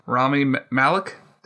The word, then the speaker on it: Rami Malek: I know I'm going to butcher this guy's name because I'm not too familiar with him. The guy from Mr. Robot, Rami Malek. – Did yep, I, I, think that's how you say it. – Rami yes, Malek. I got it.